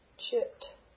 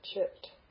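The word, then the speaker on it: Chit (चित्): Chit. Cha. Unaspirate cha. Chit. Chit. Dental T. Chit.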